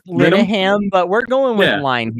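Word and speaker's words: Linehan: I loved Peep Show back in the day, Inbetweeners, all the stuff that is unfortunately affiliated with Graham Lyon Ham, or however you say his name. I grew up watching that stuff. – Lyon Ham is fine.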